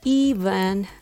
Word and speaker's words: even: There's a similar word, even. This word has the stress on the first syllable.